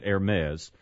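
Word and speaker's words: Hermès: It should be Hermes, but you know they're French, and so they pronounce it Hermes.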